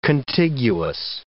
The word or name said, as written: contiguous